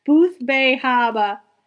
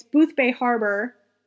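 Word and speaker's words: Boothbay Harbor: You got to say it like that. Booth Bay Harbor. It's Booth Bay Harbor.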